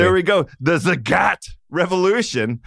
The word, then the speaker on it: Zagat: And I learned this throughout the process of the acquisition that it is Zagat, like the cat.